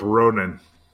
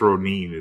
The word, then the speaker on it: Ronin: I'm going to go with Ronan. – No. – Or I guess Ronin is a proper pronunciation.